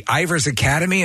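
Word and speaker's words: Ivors Academy: I mean, you're very proud of, in fact, you just received, I think it was the fellowship of the Ivers Academy.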